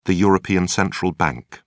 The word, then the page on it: european central bank: British English